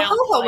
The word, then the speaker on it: jojoba: And I was like, their J's okay. So jojoba. – Like H's. Yeah, yeah. So because their J's are pronounced like an H.